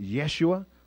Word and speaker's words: Yeshua: The name Jesus is equivalent to the Old Testament name Joshua. It would have been pronounced Yeshua or something close to that.